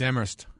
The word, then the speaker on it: Amherst: I went to school out in Western Mass many years ago, and we were always told by the locals that the H is silent in Amherst. – Yep, it's Amherst.